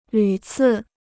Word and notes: 屡次: lǚ cì

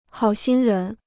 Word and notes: 好心人: hǎo xīn rén

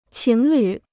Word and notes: 情侣: qíng lǚ

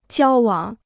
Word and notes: 交往: jiāo wǎng

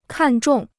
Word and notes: 看中: kàn zhòng

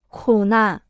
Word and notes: 苦难: kǔ nàn

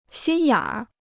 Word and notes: 心眼儿: xīn yǎn r

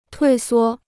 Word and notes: 退缩: tuì suō

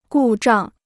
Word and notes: 故障: gù zhàng

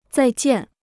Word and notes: 再见: zài jiàn

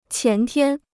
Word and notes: 前天: qián tiān

